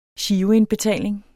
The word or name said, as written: giroindbetaling